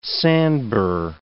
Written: sandbur

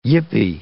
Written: yippee

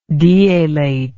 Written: dealate

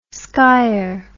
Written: skyer

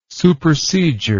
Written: supersedure

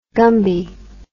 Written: Gumby

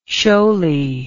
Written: shoaly

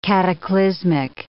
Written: cataclysmic

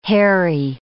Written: hairy

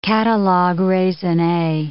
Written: catalogue raisonne

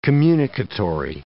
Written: communicatory

